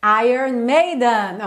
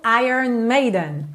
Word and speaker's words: Iron Maiden: Como é que fala mesmo o nome dessa banda? Iron Maiden, ok? Agora você já tá caprichando aí no Iron Maiden.